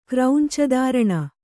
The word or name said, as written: ಕ್ರೌಂಚದಾರಣ